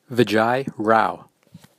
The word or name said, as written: Vijoy Rao